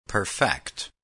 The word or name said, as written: perfect